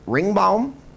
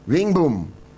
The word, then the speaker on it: Ringbom: Ringbaum? R-I-N-G-B-O-M. Ringboom. I knew I pronounced it wrong.